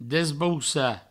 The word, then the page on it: desbaucar: Provençal; desbaussa